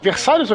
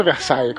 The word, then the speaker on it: Versalhes: Versalhes ou Versailles? Como é que fala? – Versalhes. Em português é Versalhes.